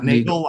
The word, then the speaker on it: Neagle: And the way you spell his last name is N-E-A-G-L-E. – And Alan used to say Neagle like bagel, not Neagle like beagle. Remember? That I don't remember, but we want to be careful. It's pronounced Neagle, but it's spelled like beagle.